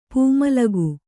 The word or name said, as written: ಪೂಮಲಗು